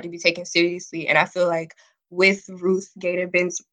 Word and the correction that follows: Ruth Bader Ginsburg: I forgot how to say her. – Ruth Bader Ginsburg.